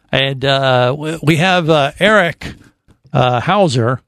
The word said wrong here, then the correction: Hanauer: Am I saying the name right, Erica? – No, it's Hanauer. – Hanauer, I apologize.